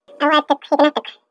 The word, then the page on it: awaatikuhiikinaahtikw: Chisasibi